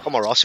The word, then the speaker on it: Comoros: Comoros, who are the newbies. – Come on, Ross.